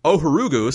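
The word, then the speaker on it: Ohuruogu: Ohorugu.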